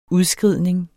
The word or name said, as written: udskridning